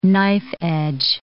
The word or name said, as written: knife-edge